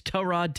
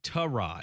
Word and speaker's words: Tyrod: So this was asked and it circulated around Browns practice this week and multiple players have confirmed the pronunciation of the name is Tyrod Taylor. – So this falls into the GIF or JIF argument. It is spelled Tyrod, but it is his name and his mother or father named him, and they said it's Tyrod.